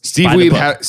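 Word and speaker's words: Wiebe: It was Steve Weeb. Steve Weeb had an audience. – That's how it's pronounced.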